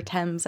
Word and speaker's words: Thames: on the River Thames, I think.